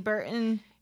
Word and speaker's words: Burton: And it's B-A-R-Y-L-A. – There you go. – And you are? – Nomi Burton. Yeah. N-O-M-I-B-U-R-T-O-N.